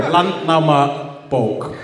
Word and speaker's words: Landnámabók: There is a book called the Landnámabók. – Sure. – Yeah, you pronounced it correctly. – Sorry, this is in 1984, right? – 874. – Landnámabók.